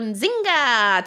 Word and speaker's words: Nzinga: Fait que si vous voyez N-Z-I-N-G-A, ben là, vous ne faites pas Nzinga, tu sais, vous savez pas, fait faites Nzinga.